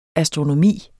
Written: astronomi

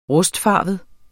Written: rustfarvet

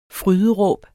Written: fryd

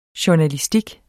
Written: journalistik

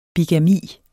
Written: bigami

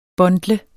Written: bundle

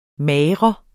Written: MAGRER

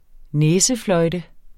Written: næsefløjte

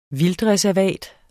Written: vildtreservat

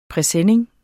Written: PRESENNINGERNES

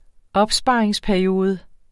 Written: opsparingsperiode

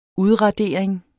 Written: udradering

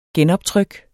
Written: genoptryk

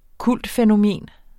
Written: kultfænomen

